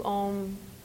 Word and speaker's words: om: So om is Sanskrit. It's actually three syllables, A-U-M, with a focus on the M.